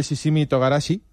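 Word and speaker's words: shichimi togarashi: Déjame aprovechar, mira, ya que ha salido esto del shishimi togarashi, ¿lo he dicho bien? – Sí, perfecto.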